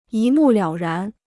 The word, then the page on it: 一目了然: yī mù liǎo rán